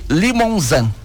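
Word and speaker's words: Limousin: Pronuncia-se Limonzan. – Limonzan. – É a pronúncia então do nome dessa raça, Limonzan. Tem que fazer biquinho, porque é francês.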